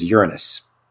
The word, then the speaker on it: Uranus: It's actually pronounced Uranus. That is the official way you pronounce that planet. It is Uranus.